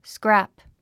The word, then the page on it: scrap: skrǽp; スクラプ